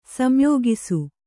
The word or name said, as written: ಸಂಯೋಗಿಸು